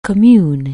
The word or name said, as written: commune